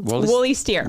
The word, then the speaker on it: Woollysteer: So how do I pronounce this correctly? You know the Woollysteer. Woollysteer. – Yes.